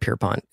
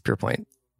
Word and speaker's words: Pierpoint: But I thought, probably like many, that he was off the show this season because he bombed out of Pierpoint at the end of last season. I'm loving his presence. – Yeah, I don't know why here. – I keep calling it Pierpont. It's Pierpoint.